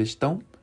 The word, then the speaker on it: estão: Como é que se escreve estão? E-S-T-A. Tio, que é aquele acento nasal, O. Ok? Quando no espanhol seria ellos están. No português você vai dizer estão. Estão. Estão ok?